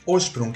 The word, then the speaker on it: Hochsprung: É, eu acho que nem eu pronuncio certo, entre muitas aspas, assim, porque eu cresci com a pronúncia brasileira dele, que é Osprung, que é como minha família chamava. Só que a pronúncia alemã certamente é bem diferente, eu nunca me arrisco a falar porque eu falando alemão sou uma porta, né?